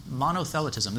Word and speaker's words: monothelitism: Mono- monothelitism.